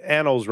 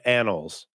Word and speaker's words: annals: I think you pronounced annals wrong. It's annals.